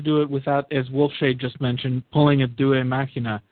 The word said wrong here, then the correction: Deus ex machina: If you say a due machina. – Deus ex machina.